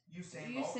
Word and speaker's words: Usain Bolt: I think he's a gold medalist, and I'm probably pronouncing his name wrong, but Usain Bolt. – Usain Bolt. – Usain Bolt. Yeah. – Usain. Yeah.